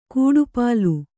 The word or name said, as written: ಕಡುಪೊಲ್ಲ